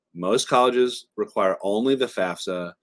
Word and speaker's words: FAFSA: FAFSA, not FASA or FASFA. Some people pronounce it those ways. They're just dumb. It's FAFSA.